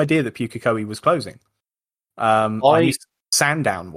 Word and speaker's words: Pukekohe: You are on about Pukekohe. – Is that how you say it? – Yes.